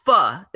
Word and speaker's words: pho: No, it was pho.